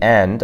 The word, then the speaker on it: yi: For example, the word E, like remember we've been saying the word for one E like that, it's actually spelled YI in the first tone, but you don't pronounce the I if it's before an I.